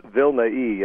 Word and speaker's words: Vilnai: By the way, it's Vilnai. It's a kind of a strange way to pronounce. It's a strange name.